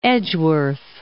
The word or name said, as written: Edgeworth